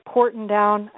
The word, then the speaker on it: Porton Down: Now, am I saying this right? Porton Down. I may be. – You are.